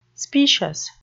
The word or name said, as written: specious